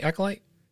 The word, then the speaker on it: Acolyte: Acolyte. Am I saying that right? Acolyte? – Sounds right.